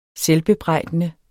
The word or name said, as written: selvbebrejdende